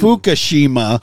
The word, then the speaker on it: Fukushima: Fukushima.